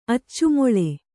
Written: ಅಚ್ಚುಮೊಳೆ

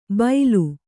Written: ಬೈಲು

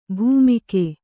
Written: ಭೈಮೂಗು

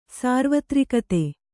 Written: ಸಾರ್ವತ್ರಿಕತೆ

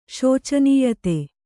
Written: ಶೋಚನೀಯತೆ

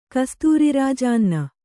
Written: ಕಸ್ತೂರಿರಾಜಾನ್ನ

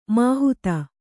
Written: ಮಾಹತ್ತು

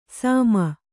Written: ಸಾಮ

